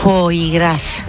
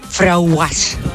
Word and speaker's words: foie gras: Foie gras. – ¿Qué? – Porque decía que tenía un nombre muy raro y ella no lo sabía pronunciar. Entonces lo decía así, frauguas.